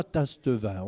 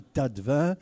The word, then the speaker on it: Tastevin: Alors, on ne dit pas Tastevin, on dit Tatevin.